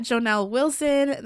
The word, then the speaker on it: Jonel Wilson: You can find me on Instagram and, well, just Instagram for now, at Jonelle Wilson. That's spelled J-O-N-E-L-W-I-L-S-O-N.